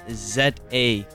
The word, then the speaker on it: Zaphyria: That's Zaphyria. I repeat, Zaphyria. Y-R-I-A. Yeah, I have to say this multiple times because nobody ever spells her name right. – Was I good? Did I pronounce it well? – Yes, you did.